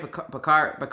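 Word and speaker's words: Bakhtiari: Am I saying that right? Bakari.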